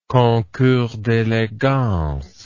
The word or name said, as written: concours d'elegance